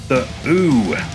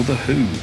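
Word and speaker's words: The Hu: The Ooh, or The Who, depending on how you want to pronounce the Mongolian band's name.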